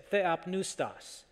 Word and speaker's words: theopneustos: It sounds like this: theopneustos. You hear the word for God, theos, in the first part, and the word for spirit, pneustos, P-N-E-U-S-T-O-S, in the second part.